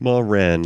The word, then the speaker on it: Maren: How do you say that? – Maren, like Mother Hen.